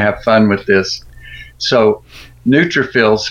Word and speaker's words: neutrophils: NEUTROPHILIS are consistently low. Is there anything I can do to raise this? What? How do you pronounce that word? – Yeah, neutrophils.